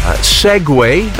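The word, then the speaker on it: segue: Segway. That's a transition from one thing to the next. Not siege, but it's segway. And at number one on the list of the most mispronounced words, it's spelt A-C-A-I.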